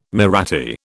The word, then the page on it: Mahratti: British English